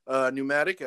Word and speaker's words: Pneumatic: Next, we have Pneumatic at plus 2,000. There's a silent P in there, so I don't really like the name of this horse, so we're not going to go with that one.